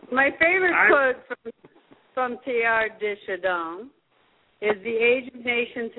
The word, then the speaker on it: Teilhard de Chardin: Well, without the Jesuits, I mean, I never would have heard or read Teilhard de Chardin. – That's right. – It's pronounced Teilhard de Chardin. Oh, de Chardin. Oh, okay, thank you.